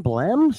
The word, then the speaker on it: Blehm: And then Mr. Bloom, or B-L-E-H-M, Blem, excuse me if I said that wrong, in that beautiful Scion FRS all white, and he is trying to track him down, Mike.